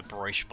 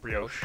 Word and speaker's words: brioche: Where they have a bacon, egg, and cheese, but it's on like a brioche bun. – Ooh, how do you pronounce that? – Brioche.